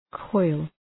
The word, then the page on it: coil: kɔıl